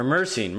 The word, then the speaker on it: myrcene: But I mean, if you're throwing in a mango and it's macked full of myrcene, micrine. I don't know. I'm not great with the pronunciation. – Myrcene. – Pepper smell. – Or myrcene. Myrcene. Sorry. It's myrcene.